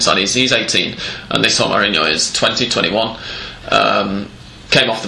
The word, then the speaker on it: Romarinho: With a goal from his first ever touch in the Copa Libertadores from Romarinho.